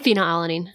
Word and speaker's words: Phenylalanine: Phenylalanine.